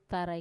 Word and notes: tarai: Wamesa

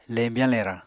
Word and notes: lembyañ-lerañ: Apatani

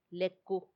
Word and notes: leku: Macuiltianguis

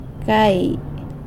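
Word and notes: gai: San Lucas Quiaviní Zapotec

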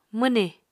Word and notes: mwnì: Apatani

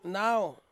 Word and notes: nau: Ansus

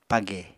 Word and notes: page: Apatani

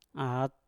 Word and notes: at: mpur

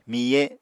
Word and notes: miye: Apatani

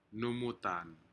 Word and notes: numutan: Aneityum

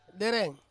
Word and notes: dereng: Ansus

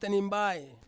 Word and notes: tanimbai: Ansus

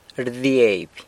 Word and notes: rdieby: sanlucasquiavini

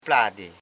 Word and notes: plade: pla.de

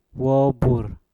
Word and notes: bubur: Mpur

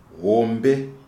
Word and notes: hombe: Shona

